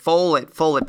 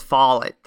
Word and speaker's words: Follett: Like, people are always like, Follett, but it's Follett.